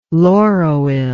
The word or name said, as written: lauroyl